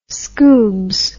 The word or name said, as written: scoobs